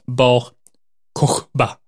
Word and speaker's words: Bar Kochba: Eh, som, var, alltså, som leddes, detta upproret leddes av en man med det fantastiska namnet Bar Kokba kanske släkt, släkt med Kubaba. Eller jag vet inte hur man uttalar det, men Bar Kokba, eller Bar Kochba, för det är KH. Bar Kochba.